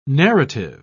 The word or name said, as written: narrative